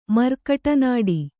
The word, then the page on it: ಮರ್ಕಟನಾಡಿ: Kannada